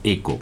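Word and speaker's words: ECHO: Bueno, se escribe Hecho, pero le decimos ECO, uh-huh, porque son las siglas en inglés.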